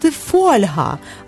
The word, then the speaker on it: фольга: Минулого тижня я була на батьківських зборах, і з великим здивуванням дізналася, що слово «фольга» правильно називають «фольга», а я все життя це називала «фольгою», і мені ніколи це не було боляче.